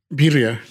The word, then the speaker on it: birria: So for like our listeners that aren't familiar with, it's birria, right? Is that how you pronounce it? – Birria. – Birria.